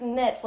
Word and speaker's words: niche: Like I said, I was like, "Is it niche or niche?" She goes, "It's niche, like bitch."